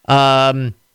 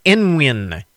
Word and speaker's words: Nguyen: The Vietnamese surname, Nguyen, or Nguyen, N-G-U-Y-E-N, Nguyen, or Nguyen, Nguyen. There are a number of different pronunciations that are acceptable for that, depending on the family. It's a surname in common in Vietnam, so different families pronounce it different ways. Gnocchi. Gnocchi. I'm sorry. See, I can't pronounce it. Gnocchi. G-N-O-C-C-H-I is sixth, while the technology controversy around the contentious word GIF is in seventh place.